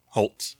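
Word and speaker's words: Hoult: I loved, how do you say his last name? Nicholas Holt? Holt? – Yeah. Holt. – Yeah.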